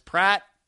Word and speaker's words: Pracht: And I think it's just because, and this happens with Eric, is the last name pronounced Pratt, P-R-A-C-H-T. He went missing, his gun went missing, everybody went out and searched, couldn't find him.